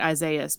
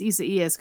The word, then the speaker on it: Isaias: Which I just learned because I've been calling it Isaias because that's how it looks like it's spelled. But apparently it's Isaias because we have to be difficult about everything in the U.S.